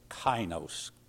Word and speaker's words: kainos: A-I is like a long I, but it's a kainos.